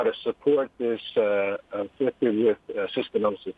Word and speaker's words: cystinosis: You're a patient advocate for cystinosis.